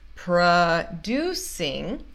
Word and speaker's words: producing: He's producing, essa primeira sílaba não é a sílaba tônica de producing, ok? Então, por isso a gente não fala pro.